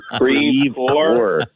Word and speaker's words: Crevecoeur: We'll talk about our hometown of Crevecoeur, which is the worst French pronunciation of those words ever. Crevecoeur.